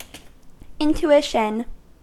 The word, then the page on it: intuition: American English